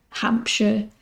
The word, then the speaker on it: Hampshire: The other one is S-H-I-R-E. What people would pronounce as Shire is actually a sh. So Lancashire, Gloucestershire, Hampshire. Again, I don't know why we pronounce it like that, but it's a thing that's universal across the UK, pretty much.